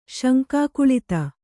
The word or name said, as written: ಶಂಕಾಕುಳಿತೆ